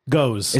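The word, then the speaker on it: Gose: It's G-O-S-E. – Goes. – Is it just goes? – Yeah.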